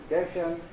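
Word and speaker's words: Geshem (גשם): And if the rabbis decide that a proper pronunciation is Geshem, so be it. Yaakov Kamenetsky said it should be Geshem,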